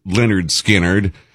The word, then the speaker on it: Lynyrd Skynyrd: Lynyrd Skynyrd, you know, they released their debut studio album, pronounced Lynyrd Skynyrd, in 1973, this week in 1973, loaded with great songs, and I want to play side one.